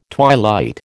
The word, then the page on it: twilight: American English